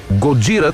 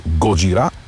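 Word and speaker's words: Gojira: Годжира, това е японската дума за Годзила. Знаем, че групата започва именно по това име. Позволявам си да произнасям с френското ударение на последната сричка. Годжира.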